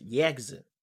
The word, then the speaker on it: Yakuza: That'd be cool, but what I'm saying is I learned from the internet that it's not pronounced however you said it. It's pronounced Yakuza.